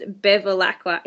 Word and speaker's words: Bevilacqua: It's exactly as it's spelled. It is honestly really easy. I think people just look at it and think, oh my God, it's hard, but it's just Bevilacqua, exactly as it is.